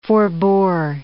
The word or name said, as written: forbore